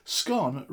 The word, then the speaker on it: scone: Scone rhymes with known. In this part of the Midlands, scone, as in how I say it, would be a posh affectation.